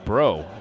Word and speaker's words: Breault: His last name spelled B-R-E-A-U-L-T, but is pronounced Bro, we are told.